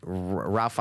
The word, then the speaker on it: Ralph: They called him Rafe. – Rafe. They said Rafe Fiennes. – Yeah, Rafe. – Yeah. – Not Ralph. – Yeah, no. – Is that his strange. – Name? – I thought it was Ralph. – I looked it up. It's spelled Ralph.